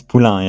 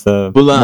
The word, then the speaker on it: Poulin: Last name is Poulin, yeah. It's Poulin. More French. More like a French pronunciation.